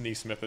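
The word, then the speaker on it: Nesmith: And then Neesmith, I think it's Neesmith is how he pronounces his name, is not nothing.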